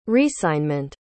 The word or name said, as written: resignment